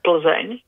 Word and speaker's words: Plzeň: It is very warm comedy set in Pilsen, which is pronounced Plzeň in the Czech language, over the summer.